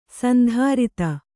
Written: ಸಾಂದ್ರತೆ

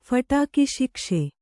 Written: ಫಟಕಿಶಿಕ್ಷೆ

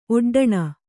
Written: ಒಡ್ಡಣೆ

